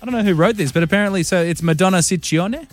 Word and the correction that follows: Ciccone: Well, in Italian, it works the other way. So the double C's are hard C's and the singular C's are soft C's. – So Ciccone.